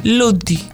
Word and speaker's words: Ludwig: Ludwig, porque él, en el sueco es Ludwig. Pero vamos a decirlo así, ¿cómo es entonces en alemán? – Ludwig. – Ludwig. – Ludwig. La W suena como Beethoven.